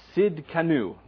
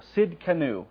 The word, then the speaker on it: Tzidkenu: Okay, so the next one is the Lord our righteousness, which in the Hebrew is Jehovah Tzedekeu. I probably said that wrong. Tzidkenu. There we go. Tzidkenu.